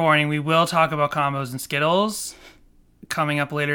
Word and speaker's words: Combos: But, yeah, Combos rhymes with shows.